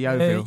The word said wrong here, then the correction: Yuval: Yuval. Yeah, like it's written, there is no secret there.